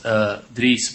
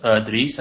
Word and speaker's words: Dries: My name is pronounced Dries Boytaert, and Dries is Dries actually is, you know, the closest thing in English is like trees, like trees in a forest, but then with a leading D. that's how I try to explain people how to pronounce my first name.